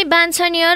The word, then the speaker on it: वाञ्छनीय: बान छ नि य बान्छनीय